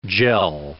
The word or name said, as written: jell